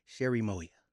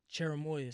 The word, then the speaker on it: Cherimoya: Sherry Moya. – Cherimoya.